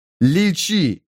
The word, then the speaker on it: личи: Всюду лячи. – Личи. Правильно говорить, личи.